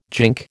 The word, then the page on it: gink: American English